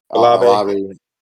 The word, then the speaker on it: Olave: So I think that I do think that, you know, a lot of these guys like Chris Olave, or how do you pronounce it? – Olave.